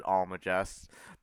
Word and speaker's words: Almagest: Yeah, I think the G is pronounced like a J here. I'm not sure, but I've always said it Almagest.